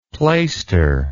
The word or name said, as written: plaister